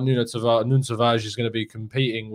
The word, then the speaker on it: Nuno Tavares: Nuno Tavares is how you pronounce it, and you'll find out how you properly pronounce it from João on the show.